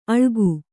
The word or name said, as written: ಅಳ್ಗು